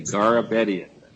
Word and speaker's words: Garabedian: And also for the respondent, Mr. Jory Garbedian. Did I come close to the pronunciation? – You got it. – Okay, Garabedian, phonetically. – Yes. Yep, that's good.